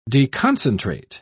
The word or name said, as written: deconcentrate